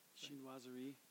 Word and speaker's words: chinoiserie: You know, but tea came over the Chinoiserie. How do you pronounce that? – Chinoiserie.